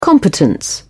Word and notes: competence: British English

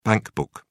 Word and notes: bankbook: British English

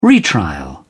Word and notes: retrial: British English

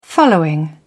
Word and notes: following: British English

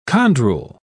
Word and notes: chondrule: British English

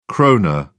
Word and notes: krone: British English

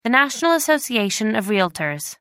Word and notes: National Association of Realtors: American English

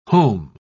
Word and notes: heaume: British English

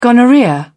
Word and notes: gonorrhoea: British English

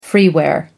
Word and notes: freeware: American English

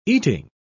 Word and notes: eating: British English

